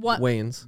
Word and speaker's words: Wayans: Wayne family? The Wayans brothers? Yeah, Wayans.